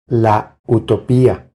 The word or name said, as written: La utopia